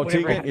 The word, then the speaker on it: Tiguan: Oh, Tiguan, yeah.